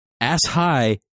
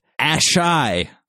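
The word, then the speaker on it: Asshai: Number one, ass high is indeed pronounced ass shy.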